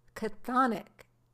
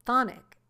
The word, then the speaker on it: chthonic: There are two ways to say this word. It was chthonic in character, or it was thonic in character. It's spelled C-H-T-H-O-N-I-C.